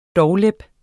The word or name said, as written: doglap